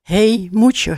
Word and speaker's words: Hey Moetje: Daarna de dampende hyping blues van Hey Moetje. Zeg ik dat goed? – Jazeker. – Ja. Oké, helemaal goed.